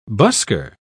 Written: busker

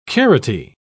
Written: carroty

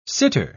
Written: sitter

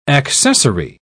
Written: accessory

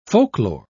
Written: folklore

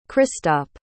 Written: christop

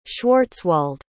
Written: schwarzwald

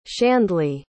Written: shandley

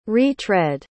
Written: re-tread

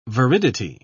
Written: viridity

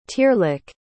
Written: tearlach

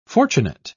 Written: fortunate

